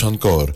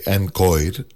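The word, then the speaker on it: Anchoir: Τους Ανκόρ, που γράφονται σαν χοροδία, Ανκόιρ, έτσι μία χοροδία, αλλά προφέρονται ως Ανκόρ.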